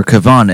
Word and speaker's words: Kevon: And starting off the set, new stuff from Kevin Edmonds, or Kevon Edmonds. I keep on mispronouncing his first name, but he was the lead singer of After Seven.